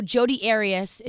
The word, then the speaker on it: Jodi Arias: So Jodi Arias, I think that's how you pronounce her name.